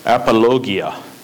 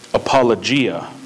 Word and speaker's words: apologia: It says apologia. A lot of people say apologia, but Greek never has the soft G or J sound in it. It's always g, not yogging. It's always gogging, not jogging, right?